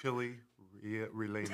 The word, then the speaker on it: chile relleno: Can you roll your R's that way? – It's a chile relleno. – That's right. You got it.